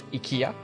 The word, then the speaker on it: IKEA: Number one, it's pronounced IKEA.